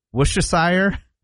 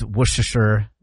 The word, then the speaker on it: Worcestershire: So you don't say the R, you don't say Worcestershire? – No.